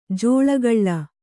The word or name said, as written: ಜೋಲುಗಾಲು